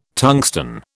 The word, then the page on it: tungsten: British English